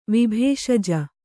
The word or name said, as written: ವಿಭೇಷಜ